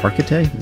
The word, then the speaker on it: Hargitay: And he really loves Mariska Hargitay.